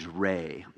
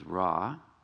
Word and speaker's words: Re: In ancient Egypt, the sun was worshipped as a deity named Re, commonly mispronounced as Ra.